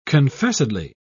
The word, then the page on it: confessedly: British English